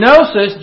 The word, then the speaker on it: gnosis: The first part is ana, A-N-A, which means again. And gnosis, G-N-O-S-I-S, the G is silent, means to know.